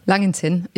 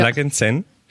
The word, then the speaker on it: Langenzenn: Und ich würde einfach mal fragen, wie hast du es geschafft, aus dem kleinen Langenzenn, spricht man das so aus? Ja. – Langenzenn, ja. Langenzenn, nach München, in die Musikhochschule.